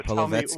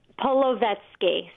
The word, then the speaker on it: Polevsky: So Polevsky. – Tell me, say it. – Polevsky. So just sound it out. Polo, then vet, then ski.